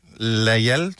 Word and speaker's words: Lajal: Lajal, sådan øh, formoder navn navnet udtales. Er det rigtigt? – Det er rigtigt.